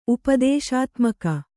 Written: ಉಪದೇಶಾತ್ಮಕ

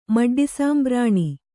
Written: ಮಡ್ಡಿಸಾಂಬ್ರಾಣಿ

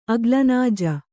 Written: ಅಗ್ಲನಾಜ

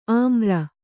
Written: ಅಮರ

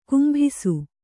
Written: ಕುಂಭಿಸು

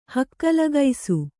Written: ಹಕ್ಕಲಗೈಸು

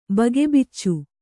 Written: ಬಗೆಬಿಚ್ಚು

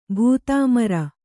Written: ಭೂತಾಮರ

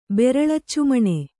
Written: ಬೆರಳಚ್ಚುಮಣೆ

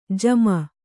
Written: ಜಮ

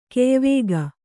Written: ಕೆಯ್ವುಗು

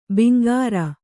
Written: ಬಂಗಾರ

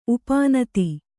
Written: ಉಪನೀತ

